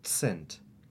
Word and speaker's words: cent: Notice that C is pronounced like TS, so cent, cent.